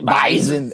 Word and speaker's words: Bison: How do you pronounce it? It's a Z. – It's like a Z. – Bison, and they upset the Bison.